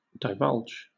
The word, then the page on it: divulge: British English